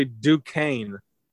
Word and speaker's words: Duquesne: How did you pronounce the university? – What did I say? DeQuincy? – It's actually Duquesne.